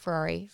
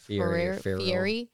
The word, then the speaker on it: Fieri: No, it's not Ferrari. Fieri.